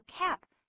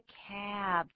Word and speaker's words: cab: 注意 cab 的发音，不要念作 cab。 Cap 就是帽子的意思，这是 cab 这么念的。